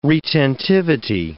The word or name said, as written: retentivity